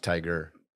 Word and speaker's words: Tyga: Actually pronounced Tiger.